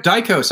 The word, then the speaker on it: Daikos: And I'm saying your name right too, right? – But Daikos, that's good. Yeah.